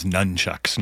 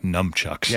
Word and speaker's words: nunchucks: I think it's nunchucks, not numchucks.